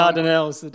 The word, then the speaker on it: Dardanelles: block the Dardanelles, and I might have said that wrong. – The Dardanelles, and the Bosphorus, to keep their vessels out.